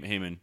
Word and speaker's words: Haman: So one of the elders at the Jubilee is he's an anti-Semitic Jewish elder, and he calls on, I can't remember how it's pronounced, Haman or Haman? – Haman. – Haman, right? Yeah.